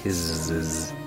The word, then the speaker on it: Kiszes: I'm pronouncing that weird because it's spelt weird. K-I-S-Z-E-S. Kizzes.